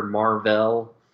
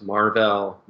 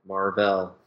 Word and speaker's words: Mar-Vell: I think it might just confuse people because, she was like a scientist, and then she befriended the Kree warrior Mar-Vell. Yeah, it was Mar-Vell, not Marvel. Mar-Vell. It's subtle.